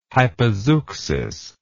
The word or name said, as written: hypozeuxis